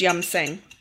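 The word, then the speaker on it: Yum Sing (飲勝): And it's spelt Yam Sing, but it's pronounced Yum Sing.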